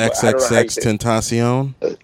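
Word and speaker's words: XXXTentacion: I don't even know how to say his name. X-Tenation, is that? – X-X-X-Tentacion? – That's it, yeah.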